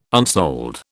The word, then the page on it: unsold: British English